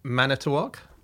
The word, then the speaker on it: Manitowoc: Manitowoc. – Okay, this is an email from Alex. Andy, just wanted to say that pronounced it completely correctly. It was like he was from Wisconsin.